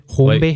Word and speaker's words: Hornby: Hornby. – Like, no, like, it's, I don't know how you say it. Is it Hornby? Like, the fucking, the train? So, like, Skelectric, but trains? – Yes.